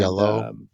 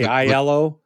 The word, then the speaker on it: Iello: Yellow. I always say Iello. – Like Danny. – Danny Aiello. Yeah. – Yeah. It's yellow. Think of it. – Yellow. – Like, pronounce it like the color.